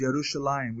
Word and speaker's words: Yerushalayim: it's actually Lachish and some of these other names, Yerushalayim.